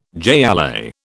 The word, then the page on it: jai alai: British English